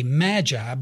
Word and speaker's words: Magi: As mentioned earlier, some say Magi, but again, the correct pronunciation, whether American English or British English, is Magi.